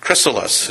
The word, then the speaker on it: Chrysalis: Chrysalis is how you say it.